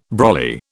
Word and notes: brolly: British English

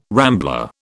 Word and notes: rambler: British English

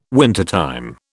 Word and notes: wintertime: British English

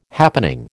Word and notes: happening: American English

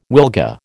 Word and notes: wilga: American English

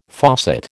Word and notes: faucet: American English

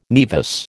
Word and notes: nevus: American English

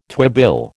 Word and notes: twibill: American English

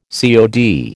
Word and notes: COD: American English

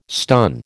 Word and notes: stun: American English

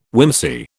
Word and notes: whimsy: British English

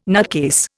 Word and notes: nutcase: Indian

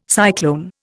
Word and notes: cyclone: Indian

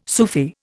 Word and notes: Sufi: Indian

